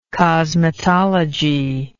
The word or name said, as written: cosmetology